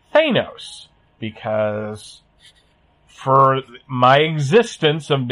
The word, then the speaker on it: Thanos: we would say Thanos. And this 4-year-old corrected me and said, no, it's Thanos, because apparently that's how they pronounced it on the cartoon that was on at the time. – Yes. – Imagine my surprise when, even though they've screwed it up in the first Guardians, that's apparently the official pronunciation.